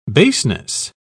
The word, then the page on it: baseness: British English